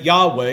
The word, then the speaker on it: Yahweh: Actually, there is a complaint that Yahweh, and that's His name if you didn't know, God's name is Yahweh, the best we can pronounce it.